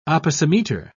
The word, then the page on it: opisometer: British English